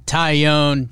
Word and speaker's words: Taillon: We are joined by the newest New York Yankee and coffee connoisseur, Jameson Taillon.